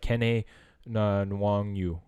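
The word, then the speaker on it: Kene Nwangwu: But I did hear that, hopefully I say this right, Kene Nwangu.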